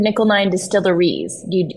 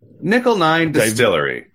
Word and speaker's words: Nickel 9 Distillery: Is it Nickel 9 Distillery? Or because I've also heard Nickel 9 Distilleries. What's the proper way to say it? – Distillery.